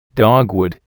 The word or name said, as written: dogwood